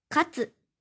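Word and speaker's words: 勝つ: 训读的时候有两种读法，第一种读作 katsu katsu，这里的词是送假名，katsu 是胜或胜过的意思。另一种训读读作 masaru。马萨鲁，这里的鲁是送假名，马萨鲁是胜过或比什么什么好的意思。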